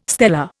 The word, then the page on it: stela: Indian